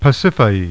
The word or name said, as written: Pasiphae